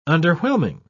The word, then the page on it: underwhelming: British English